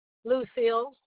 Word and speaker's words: Lucille: Lucille.